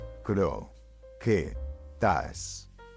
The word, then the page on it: Croquetas: Spanish